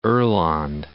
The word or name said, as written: oland